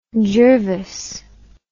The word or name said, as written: gervase